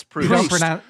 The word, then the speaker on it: Proust: It's Proust. – You don't pronounce the T.